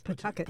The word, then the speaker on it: Pawtucket: A journalism teacher at Ohio State who told us if you say Pawtucket, Rhode Island, they know you don't belong. It's Pawtucket.